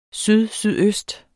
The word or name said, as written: sydsydøst